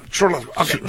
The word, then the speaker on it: Shrek: Yo nunca he pronunciado yo Shrek. – ¿Es Shrek? – A ver, ¿cómo? – Shrek. – Shrek. – Shrek, ok. – Así tal cual. – Shrek.